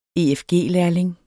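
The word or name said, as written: efg-lærling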